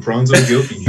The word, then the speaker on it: Fronzo Gilkey: Boom, Fronzo Gilkey.